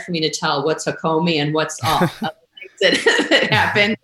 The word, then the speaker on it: Hakomi: You mentioned Hakomi earlier. Am I saying that correctly? – Yeah, that's right.